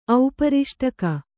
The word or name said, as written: ಔಪರಿಷ್ಟಕ